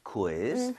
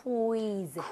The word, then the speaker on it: quiz: 아, 그나저나 읽을 때 이거 퀴즈라고 하지 마시고요. 우이를 따로 다 발음해야 돼요. – Quiz. – Quiz. 그 대신에 빠르게 하는 것 뿐이에요. So, quiz 아니라 quiz. Quiz. 좀 이런 식으로 퀴 말고요.